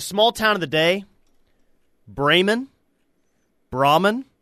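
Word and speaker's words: Braman: Very familiar with Brahman, I believe is the correct pronunciation, as far as I am aware.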